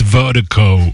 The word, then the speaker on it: Virdiko: And Vertigo, Vertigo is spelled V-I-R-D-I-K-O. That's V-I-R-D-I-K-O, pronounced Vertigo.